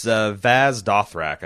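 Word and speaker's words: Vaes Dothrak: I thought I had the pronunciation in my head, but it's Vaz Dothrak, I think is how you pronounce it.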